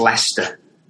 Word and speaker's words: Leicester: It's spelled Leicester, but it's pronounced Leicester.